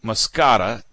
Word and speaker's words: moschata: And there's another group of pumpkins, and this is a really important group, Moscata, M-O-S-C-H-A-T-A, Moschata. Moschata, I don't care. You can pronounce it either way. You don't care either, do you? Moschata, Moscata, M-O-S-C-H-A-T-A.